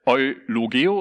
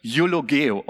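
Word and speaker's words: eulogeo (εὐλογέω): Ja, hier, wenn man es so im Deutschen sieht, würde man vielleicht eulogeo oder sowas sagen, aber im Griechischen wird es ungefähr eulogeo ausgesprochen. Und es ist ein zusammengesetztes Wort und hat seine beiden Wurzeln in dem Wort eu und lagas.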